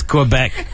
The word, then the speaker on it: Quebec: Is that not how you say it? – No, it's Quebec.